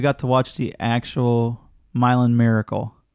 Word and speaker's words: Milan: And yes, I know it's spelled Milan. – Yeah, because well, I said it was Milan too. – This is Indiana, so it's pronounced Milan there.